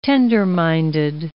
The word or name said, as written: tender-minded